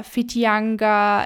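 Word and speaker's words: Whitianga: Meistens sind es dann ziemlich ja, kompliziert klingende Wörter wie Hokitika oder Fitianga, also alles, was mit WH am Anfang geschrieben wird, wird F ausgesprochen.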